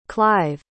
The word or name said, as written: clyve